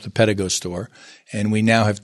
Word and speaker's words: Pedego: The name Pedego was created by us, and it basically is pedal, add a little bit of E, and then you go. So it's Pedego. Pedego.